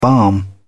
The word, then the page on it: balm: British English